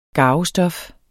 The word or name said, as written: GARVESTOF